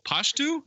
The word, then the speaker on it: Pashto: Then Dan Aykroyd pulls out Chevy Chase pulls out this booklet that's of conversational Pashto. Is that how you say it? – Yes.